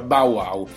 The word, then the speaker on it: Bauwow: Comunque si va e si scarica questa applicazione, si scrive proprio Bow Wow, quindi Bow, sì, Bologna, Ancona, Udine, Whisky o Otranto Whisky, esattamente, è molto semplice come si pronuncia, Bow Wow,